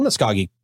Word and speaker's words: Muscogee: Did I say that right? – Muscogee? Yes, you did.